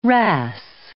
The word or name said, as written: ras